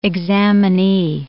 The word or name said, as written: examinee